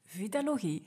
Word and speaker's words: Vitalogie: Zij is eetstoornistherapeute van Vitalogy volgens mij heb ik vitalogie al verkeerd uitgesproken, want het is... Vitalogie? Het is vitalogie, ja, sorry.